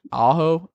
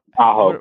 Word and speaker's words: Aho: Aho. – Aho.